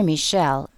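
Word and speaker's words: Mischel: "I'm with Mr. Michel," pronounced Michel, "in his tiny home office in Paris, where he spends the summer with his girlfriend.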